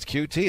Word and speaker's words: Coutee: But Coutee did say it's Coutee. It's like the I letters guy Coutee. asked him yesterday, and he said Coutee.